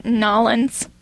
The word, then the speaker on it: New Orleans: Here in New Orleans, or Nolens, as they say it, right? – Yeah.